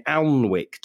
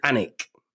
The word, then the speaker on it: Alnwick: I was calling it Alnwick. Do you know this is up near Scotland? Alnwick? – Never heard of it. – This tour of mine is going everywhere, mate. apparently you pronounce it Anick.